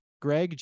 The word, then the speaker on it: Gregg: but is Greg G R E G G, and my last name is Schigiel S C H I G I E L.